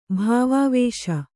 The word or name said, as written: ಭಾವಾವೇಶ